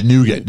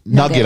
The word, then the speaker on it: nougat: Americans are going to hear nugget. Like a chicken nugget. – Okay, yeah, so nougat. – N-O-U-G-A-T. Yeah, pronounced here as nugget, I guess, in places. Yeah. In North America, we would call it nougat.